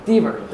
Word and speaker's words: Dever: And here, a little bit faded, and the background of some of the language of the text from the Chicago Chronicle is, I believe I'm pronouncing his name what right, Mayor William Emmett Dever, or Dever? Dever, thank you.